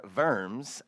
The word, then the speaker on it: Worms: Well, the diet of worms, or verms, as it is pronounced, that we're going to talk about this morning has nothing to do with eating or dirt dwellers.